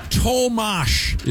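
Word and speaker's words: Tomáš: Tomas is how you say the first name. I thought it was Tom Hurdle. It's not.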